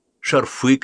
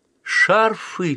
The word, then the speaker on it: шарфы: «Шарфы, Леша, шарфы!» Ударение на «а» запомнят на всю жизнь.